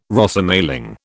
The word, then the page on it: Rosemaling: British English